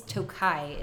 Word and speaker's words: Tokaj: I know it's Tokaj is how you pronounce that. And what I learned is that the I at the end of it means that it's of.